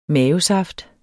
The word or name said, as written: mavesaft